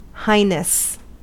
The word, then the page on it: highness: American English